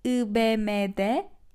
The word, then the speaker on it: IBM: In Turkish, for sure, we need to pronounce these letters like in Turkish. So let's say IBM'de yeni işe başladım. IBM'de yeni işe başladım. But for sure, we sometimes say in English.